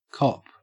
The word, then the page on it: cop: Australian English